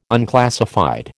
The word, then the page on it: unclassified: American English